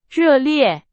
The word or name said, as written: 热烈